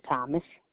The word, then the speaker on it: Thomas: Yvonne Thomas. Y-V-O-N-N-E. Last name Thomas, T-H-O-M-A-S.